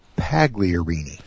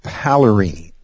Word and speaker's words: Pagliarini: Pagliarini. All right. Is that better? – Well, you actually don't pronounce the G, but I'm still impressed. – Okay. – Paglarini. – Okay. Paglarini.